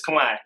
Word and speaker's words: Khmer: I've seen the word Khmer and I always said Khmer, you know, and I'm like, am I saying it right? Is it Khmer? – It's Khmer. You're okay. Saying it good.